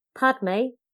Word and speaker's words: Padme: Padme, O, a combination of the English words pad and me.